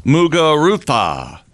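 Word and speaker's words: Muguruza: G-A-R-B-I-N-E. – Garbini? – Garbine? – Garba. Any good Americans at all in this? – Mugurutha. That's the pronouncer. – Mugurutha? – Yeah, but you spell it M-U-G-U-R.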